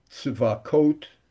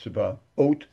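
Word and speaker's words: Tzevaot: But the Yud-Kei Vav-Kei name and Elokim, Elokeinu, Eloach, Shin Dalet Yud, Tzivakot, which is pronounced Tzivaot, Kael, Ka, those are the names that you're not allowed to erase.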